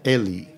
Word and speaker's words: Egly: How did you pronounce his name? – Francis Ely. – E-G-L-Y? – Yep.